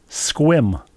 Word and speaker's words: Sequim: S E Q Sequim? – Squim. – Squim. – S K W I M, yes.